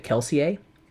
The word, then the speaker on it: Kelsier: And Brandon Sanderson had told my friend that uh, in his mind he always pronounced it Kelsier, like with a French mm-hmm. word on it, kind of thing.